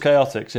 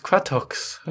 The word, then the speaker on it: Chaotix: I was like, I think it's pronounced Quatox. I was like, and then it wasn't until I heard on a VHS it's Chaotix.